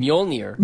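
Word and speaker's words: Mjölnir: Mjolnir. – Mjolnir. – There's a soft J in there, which makes the y sound.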